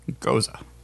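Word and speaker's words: Gose: Pronounced go-ze. – Goza. – Yeah, it was just pronounced go-za. Like that.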